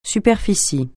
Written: superficie

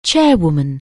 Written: chairwoman

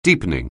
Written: deepening

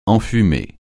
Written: enfumer

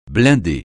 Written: blindé

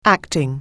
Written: acting

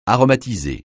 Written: aromatiser

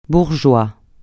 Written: bourgeois

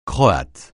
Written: croate

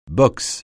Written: boxe

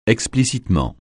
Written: explicitement

